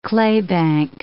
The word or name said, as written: claybank